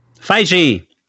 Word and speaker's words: Feige: I was told definitively from Marvel it is Feige. – Feige. – Feige. As opposed to Feige, Feige, Foji, Foji.